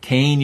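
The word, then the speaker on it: Kean: So tonight we're welcoming back Dr. Brian Regal, assistant professor for the history of science, technology, and medicine at Kane University in New Jersey. And if I've called it Keene in the past, I was saying it wrong.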